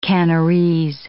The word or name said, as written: Kanarese